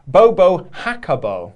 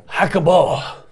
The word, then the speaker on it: Jacobo: When you pronounced it in English, it's actually supposed to be in Spanish. It's pronounced Bobo Jacobo. – Jacobo. – With more emphasis on the co, so Bobo Jacobo, Jacobo, Jacobo.